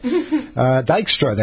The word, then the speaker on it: Dijkstra: And the fourth track, the one I can't pronounce, Dijkstra, it's D-I-J-K-S-T-R-A. If anyone can pronounce that better, please have at it. What's that? – Is that just Dijkstra? – Is that right? – I think so.